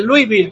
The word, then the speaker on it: Louisville: Para el equipo de Louisville.